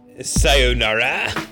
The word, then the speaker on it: sayonara: say, sayonara. Is that how to pronounce that? – Yeah, you got it.